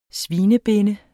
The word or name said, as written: svinebinde